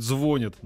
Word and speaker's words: звонит: Фадеев, прекрати говорить, звонит. Нормативно ударение на последний слог. Ударение на «о» неправильно, каким бы ни был смысл глагола в данном контексте.